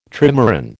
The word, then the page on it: trimaran: American English